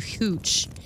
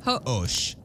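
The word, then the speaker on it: Hoche: Say it's osh. – Osh, like you osh. Like the H is silent ish. – The H is, I mean, it's French. The H is almost always silent.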